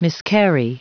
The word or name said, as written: miscarry